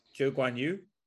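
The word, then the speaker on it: Zhou Guanyu: Zhou Guan Yu.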